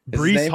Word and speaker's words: Breece: Breece? – Breece Hall, yeah.